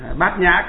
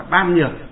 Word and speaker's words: bát nhã: Nhà Phật thì đọc là nhã, bát nhã có nghĩa là trí tuệ, ở ngoài đời họ đọc là ban nhược.